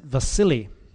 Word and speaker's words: Basileia: That word there, Vina tells me you pronounce it Vasili. That's Bill's Greek name. Basileia.